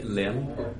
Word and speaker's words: Lim: Rithi Lim, last name L-I-M.